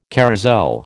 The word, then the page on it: carrousel: American English